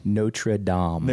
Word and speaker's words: Notre Dame: No, you have to say the French pronunciation. – Notre Dame. – There you go.